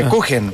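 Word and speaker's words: Kuchen: Sí, una pastelería que encuentran en Providencia, que nos ofreció estas galletas y un crujen, ¿cómo lo llaman ustedes? ¿Crujen? – Cujen. – Cujen, cujen. – Ah, no se dice, claro, se trae. En Chile como que no se españoliza tanto eso.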